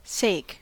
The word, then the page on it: sake: American English